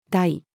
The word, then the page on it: 大: female